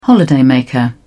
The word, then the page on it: holidaymaker: British English